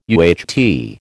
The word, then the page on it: UHT: American English